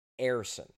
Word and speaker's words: Ersson: So the way he apparently prefers to be called Sam, not Samuel, and the correct way to pronounce his last name is Ersan.